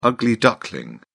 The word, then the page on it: ugly duckling: British English